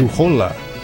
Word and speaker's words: Juhola: So, as we are, we've got right now Joanna Juhula. Dang, I always say her name wrong. – Joanna about right. – Juhola. I get the U and the O mixed up, and I don't speak Finnish anyways, so I don't know.